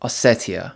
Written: Ossetia

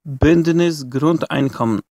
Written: Bündnis Grundeinkommen

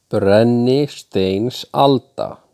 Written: Brennisteinsalda

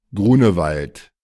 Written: Grunewald

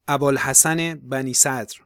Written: Abolhassan Bani-sadr

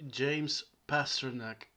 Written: James Pasternak

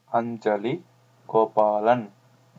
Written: Anjali Gopalan

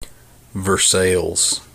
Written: Versailles

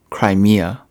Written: Crimea

